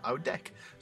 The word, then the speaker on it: Oudijk: Wim is spelled W-I-M, but he pronounces it Vim Oudek. So O-U-D-I-J-K.